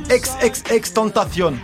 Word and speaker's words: XXXTentacion: Alors, je vais essayer de le prononcer correctement. XXXTentacion. C'est bon ? – C'est pas mal.